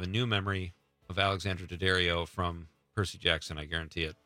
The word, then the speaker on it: Daddario: Daddario.